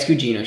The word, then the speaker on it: Gugino: But yeah, it's Gugino.